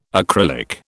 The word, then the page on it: acrylic: British English